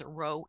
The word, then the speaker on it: Roi: It's spelled R-O-I. I've heard a lot of people say it as Yahweh Roi. Incorrect pronunciation. It is Roe, Yahweh Roe. The Lord is my shepherd.